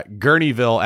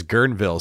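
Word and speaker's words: Guerneville: Uh, I also noticed that Zeona pronounced uh, Gurneyville as Gurnville,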